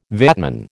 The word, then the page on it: VATman: American English